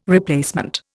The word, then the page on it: replacement: Indian